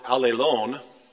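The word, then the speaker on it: allēlōn: The Greek word, this isn't that important, but it's kind of interesting, is pronounced alelon, which in English almost sounds like all alone, and it means exactly the opposite, all right?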